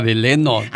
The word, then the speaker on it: Delano: Pero dice Delano. – Así se escribe, pero se pronuncia Delano.